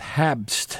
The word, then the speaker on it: Habst: We started with the DJ and producer, Sean Porter, who goes by the name of Bloody Snowman, with Habst. I don't know if I'm pronouncing that right. H-A-B-S-T, like Pabst Blue Ribbon. – Mm-hmm. – Habst. Part of an album called, K-L-Z-X-R-M-X, that's all capitals, the KlezX Remixed, a various artists collection of Klezmer remixes.